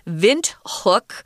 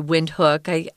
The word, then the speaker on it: Windhoek: And remember, we said the capital city is actually named Windhoek, which is the German pronunciation. You can also say Windhoek.